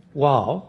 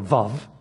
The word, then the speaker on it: Vav: And the next section, Wow, or Vav, as it's pronounced in modern Hebrew, means roughly and.